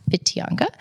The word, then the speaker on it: Whitianga: We ended up living in a pretty small town called Whitianga, spelled Whitianga, but WH in New Zealand is pronounced F. So there, if you ever want to look really cool to New Zealanders, you pronounce Whitianga.